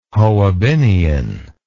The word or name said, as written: hoabinhian